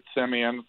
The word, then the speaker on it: Semien: So the takeaway from that is that Boras pronounces it Semien, and that he's worse with the dad jokes than I am.